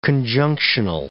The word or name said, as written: conjunctional